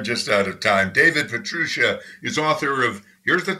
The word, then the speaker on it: Pietrusza: Well, the standard pronunciation in this country is Pietrusza. She would say Pietrusza,